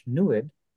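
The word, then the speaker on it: NEID: And in their language, NUID rhymes with fluid.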